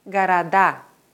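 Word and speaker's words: города: Im Plural wandert die Betonung vom Wortstamm auf die Endung. Städte heißt Garada. Es ist also wichtig, die Wörter und Wortformen gleich mit der richtigen Betonung zu lernen.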